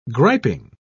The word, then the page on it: griping: British English